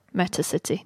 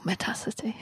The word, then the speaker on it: Metacity: So once they'll have to look at it and go, Metacity. No, Metacity.